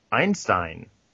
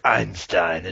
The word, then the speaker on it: Einstein: In a world where Einstein is pronounced with an I, even though it starts with an E.